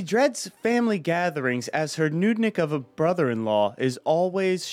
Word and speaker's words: Nudnik: The pronunciation has the double O, and there's an additional note you have here for me. The O-O is as in good, and so it's a nudnik.